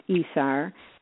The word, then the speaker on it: ESR: So this one is called ESR, which is pronounced ESAR.